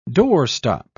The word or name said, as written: doorstop